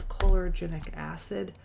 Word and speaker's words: chlorogenic acid: Let's see, I think it's spelled chlorogenic acid.